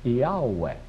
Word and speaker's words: Yahweh: They, in fact, transliterated it into Greek in these letters, I-A-O-U-E. I-A-O-U-E. Why those letters? You pronounce it, Yahweh. That's the Greek pronunciation of those letters, Yahweh.